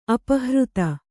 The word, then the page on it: ಅಪಹೃತೆ: apahřte